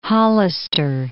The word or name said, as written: Hollister